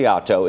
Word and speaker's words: Oleato: It's called, and I don't know if I'm going to pronounce Oleato. – This properly. Oleato.